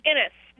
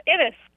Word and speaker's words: Innes: Innes. – Innes. Okay. I like that. Sounds like Ennis. Like John Ennis. – Yeah. – Innes. Innes rhymes with Guinness. It's Scottish, so.